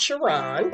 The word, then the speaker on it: Sherron: Um, it's pronounced Sharon.